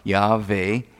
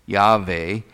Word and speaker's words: Yahweh: And the name I Am That I Am implies, it's the name Yahweh, or Jehovah, Yahweh, as it's pronounced in the Hebrew tongue, Y-H-W-H, Yahweh.